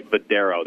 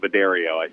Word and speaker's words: Vadero: They want me to make sure that we say Vadero on the pronunciation. It does look like Vidario. I agree with you. But by Daredevil, it started to all kind of make sense to me after a second. I started to put it all together. I said, okay, I get it. Vadero is how you say that name.